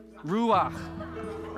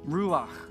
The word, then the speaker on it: Ruach: This word wind here in the Hebrew is ruach. Say that with me. Ruach. Right? That is the wind. That is breath. That is the spirit. Do you see the common theme here? Ruach. Wind, breath, spirit. How do I spell it? R-U-A-C-H. R-U-A-C-H.